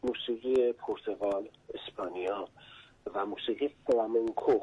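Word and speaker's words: flamenco: که اشتباهاً فلامینگو تلفظ میشه، که فلامینگو اسمی که پرنده است. – بله فلامنکو. – فلامنکو. بله. در از این فلاح منگوم هست. – در واقع اسپانیایش میگه فلامنکو.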